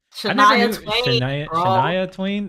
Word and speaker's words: Shania Twain: Shania Twain.